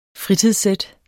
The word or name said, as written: fritidssæt